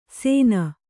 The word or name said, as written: ಸೇನ